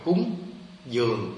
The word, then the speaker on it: cúng dường: Mình đọc cung hoặc là cúng, dường mình đọc là dưỡng, giáo dưỡng, dưỡng là nuôi. Thì người ta đọc cúng dường nó vì nó hai âm.